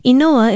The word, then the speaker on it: inoa: Inoa is spelled I-N-O-A and is pronounced inoa.